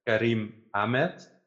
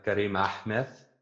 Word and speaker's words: Kariem Ahmed: Like I have the Dutch way to say it. It's like Kariem Ahmed. That's the Dutch way. But I think it's officially like Kariem Ahmed.